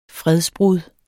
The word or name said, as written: fredsbrud